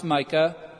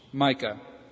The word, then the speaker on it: Micaiah: And there we find Micah, M-I-C-A-I-A-H, Micah.